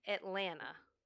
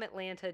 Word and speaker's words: Atlanta: Because most people from Atlanta do not pronounce the second T.